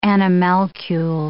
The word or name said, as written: animalcule